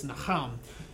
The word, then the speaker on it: naham: This word for sorry in Hebrew, it's naham. Which in English you'd spell it N-A-H-A-M.